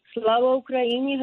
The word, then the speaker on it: Slava Ukraini: Slava Ukraine? – Slava Ukraini, Heroev Slava.